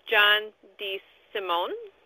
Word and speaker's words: John DeSimone: I'm here with my special guest today, John DeSimone.